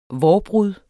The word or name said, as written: vårbrud